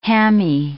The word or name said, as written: hammy